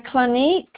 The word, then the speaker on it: Clinique: I Clinique. – Yes, ma'am. That's how you say it.